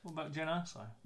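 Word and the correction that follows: Jyn Erso: Or Jin Erso, I said it wrong.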